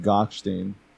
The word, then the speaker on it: Gochstein: You can pronounce it like 13 different ways. – Say it the way you say it. – I mean, Gochstein.